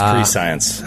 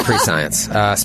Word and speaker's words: prescient: It's pronounced pre-science. – I'm sorry, yes, pre-science.